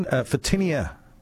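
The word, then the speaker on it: Photinia: Fitinia, did I pronounce that correctly? – Yes, you did.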